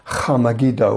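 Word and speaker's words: Har Megiddo: In Hebrew, it's pronounced Ha-Megiddo or Armageddon in Greek.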